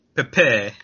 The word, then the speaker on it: Pepe: PayPay, or Pee-Pee, mm-hmm. – Or Peppy. How would you say it? You're of that descent. – Pepe. – Oh, okay, like there was 3 P's in the front? – Yeah.